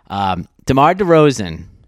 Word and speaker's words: DeMar DeRozan: DeMar DeRozan, am I pronouncing that right? – You are.